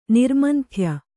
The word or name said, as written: ನಿರ್ಮಂಥ್ಯ